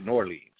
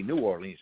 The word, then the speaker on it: New Orleans: And at first, when the friend down there said New Orleans, I didn't really believe he was from New Orleans, because everybody I know in New Orleans says Norleans. They pronounce it Norleans. If you say New Orleans, they know you're from up north.